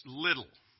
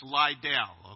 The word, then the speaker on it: Liddell: In Scotland, it's Little. In other English, it's Liddell, okay?